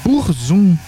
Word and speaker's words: Burzum: Como se escreve Burzum? – Burzum. Z-U-M. – Ah, normal. – Mas fala Burzum.